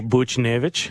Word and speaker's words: Buchnevich: He's the next Buchnevich. Is that how you say it? – Yeah. Okay. Pavel Buchnevich. – Buchnevich. Gagarin Cup.